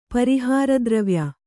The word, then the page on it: ಪರಿಹಾರದ್ರವ್ಯ: pari hāra dravya